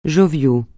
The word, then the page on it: jovial: francais